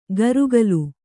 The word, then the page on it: ಕರುಕಲು: karukalu